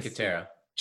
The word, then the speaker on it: Cinque Terre: cinque terre is up in the north. – Chikatera. – Cinque Terre, I'm sorry, my pronunciation is absolutely horrible.